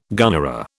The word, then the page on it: gunnera: British English